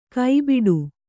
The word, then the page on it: ಕಬಾಡ: kabāḍa